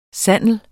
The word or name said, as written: sandel